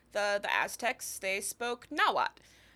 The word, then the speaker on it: Nahuatl: Um, so that's spelled N A H U A T L. So the TL that you see appear at a lot of at the end of a lot of Aztec words, mm-hmm. – your white professor probably pronounced it "tull." Mm-hmm. You do not pronounce it that way. That is a uh, like um it's it's actually it's a it's a unique phoneme of its own